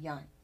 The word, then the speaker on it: yang: And you've talked about this before, Sam, where you have one, you have to have the opposite, the yin, and you corrected my pronunciation. I said yang, you said yang.